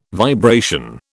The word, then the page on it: vibration: British English